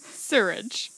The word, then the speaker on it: sewerage: Swearage. – Oh my gosh. – Okay, you say it. – Sewerage.